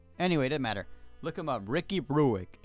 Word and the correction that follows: Berwick: If you know him, Brewick, Ricky Berwick. Fuck, man, I always pronounce it wrong.